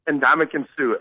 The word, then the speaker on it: Ndamukong Suh: Ndamukong Suh. Okay. Ndoumkongsuh.